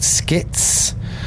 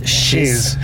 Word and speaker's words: Schiz: I'm doing a show called Skits, which is spelled S-C-H-I-Z, which everyone instantly pronounces as shiz. Thank you. Jesus. – But it's skits, so, as in skits.